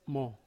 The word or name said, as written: mo